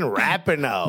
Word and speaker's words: Rapinoe: Yes, forget Megan Rapinoe.